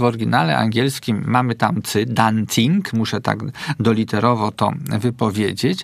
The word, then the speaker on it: dancing: Natomiast wymowa może być zawsze tylko przez sy, czyli na dancingu byłem i na dancingu się bawiłem i na dancing się wybieram.